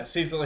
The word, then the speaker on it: Cecil: Was that how you say his name? Cecil? – Yeah, Cecil.